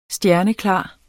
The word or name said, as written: stjerneklar